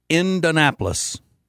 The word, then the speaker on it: Indianapolis: Or unless you are from Indiana, it's pronounced Indianapolis. That's where my wife's crazy family is from. Not Indianapolis, but they're from Muncie. But they still say they're going to Indianapolis. They leave a syllable out of it.